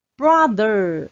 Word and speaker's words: brother: Brother，哥哥弟弟，Brother，这边还是有个 th 的音，发音要稍微注意一下哦。